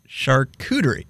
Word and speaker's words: charcuterie: It's charcuterie.